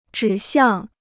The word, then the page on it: 指向: zhǐ xiàng